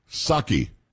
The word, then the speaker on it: Psaki: Say, repeat after me. Psaki. – Psaki? – Psaki. It's not Psaki. It was just Psaki. You didn't pronounce the P.